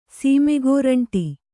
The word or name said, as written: ಸೀಮೆಗೋರಂಟಿ